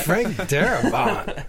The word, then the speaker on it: Darabont: Frank Darabont.